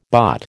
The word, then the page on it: bott: American English